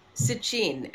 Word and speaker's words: Sitchin: Sitchin, yes. Sitchin, actually, if you pronounce it properly.